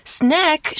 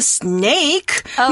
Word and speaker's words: snack: Snack 是点心的意思。那念到这个字，同学们一定要特别注意，它是短母音 a 的声音 snack。如果你不幸念成了 snake,、oh.